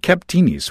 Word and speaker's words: Kaptinis: and pardon my attempt at pronunciation, Kaptinis. So did I get close? – Yeah, you did. Yeah. – So talk about Kaptinis.